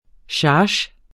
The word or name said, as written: charge